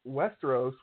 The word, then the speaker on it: Westeros: Okay, so SportsCenter actually put on a odds to see who would rule Westeros when Game of Thrones ends, and is his name Bronn Stark? – Bran Stark is the leader to rule Westeros when it's done at a 10 to 11 odds.